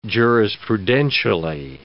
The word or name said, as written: jurisprudentially